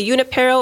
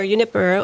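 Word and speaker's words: Junípero: And so he entered and took the name, well, you could say Junipero or Junipero. It depends where you're at. If I'm California, how you say his name. Okay. Both are correct.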